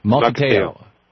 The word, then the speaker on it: Mukilteo: Mukilteo.